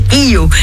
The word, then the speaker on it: IOHIYO: Elles s'associent pour donner naissance à Io, I-O-H-I-Y-O. Alors, comment vous le prononcez? On ne sait pas. Toujours est-il que phonétiquement, ça se prononce Io.